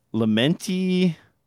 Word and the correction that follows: Luminati: CS1, am I saying that right? Luminati, maybe? – Luminati CS1.